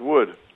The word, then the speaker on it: Wode: Has the WODE ever been pronounced as Wode House in any in the history? You said it went all the way back near 1066. – No, it's pronounced Wood.